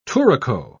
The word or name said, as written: touraco